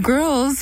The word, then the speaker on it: GRLS: Then you listen to Girls by Twee, that's spelled G-R-L-S, take out the I.